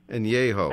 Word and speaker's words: Añejo: It's A-N-E-J-O. What? How do you say? – Añejo. – Añejo. – There we go. – Añejo is pronounced, yeah.